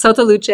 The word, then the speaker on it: Sotto Luce: No, that's correct. It's Soto Luce. It's Italian, and you got it right.